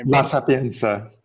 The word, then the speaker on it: La Sapienza: La Sapienza.